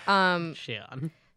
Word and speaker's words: Xion: Xion.